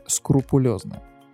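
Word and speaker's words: скрупулёзно: Кстати, не так давно случайно выяснил, что правильно говорить скрупулезно. Скрупулезно? Да. От слова скрупулы это типа какая-то мельчайшая частица.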